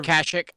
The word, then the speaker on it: Kashyyyk: Why Life Day is a celebration traditionally held at the Tree of Life on how do you say that again? Kashyyyk. And observed. – Or Kashyyyk. I've heard it both ways.